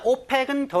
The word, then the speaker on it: OPEC: 조직의 이름, 기관의 이름을 약자로 이렇게 네 단어 이상을 쓸 때 발음대로 오펙 이라고 읽으면 OPEC가 아니고 OPEC 발음대로 읽으면 덜을 쓰지 않고요. OECD 철자 하나하나를 끊어 읽으면 덜을 씁니다.